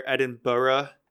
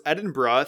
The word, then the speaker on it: Edinburgh: But yeah, so it's Edinburgh. Think of just, you know, when people say bruh, like, bro, what's up, bruh? How you doing, bruh? That's how you pronounce it, Edinburgh.